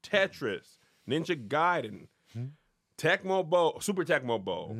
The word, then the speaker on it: Gaiden: Gaiden. – Oh, it was Ninja Gaiden? – Pronounced Gaiden.